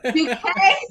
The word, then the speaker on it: Duquesne: Duquesne University through their Department of Psychology and Rita McGinley Clinic.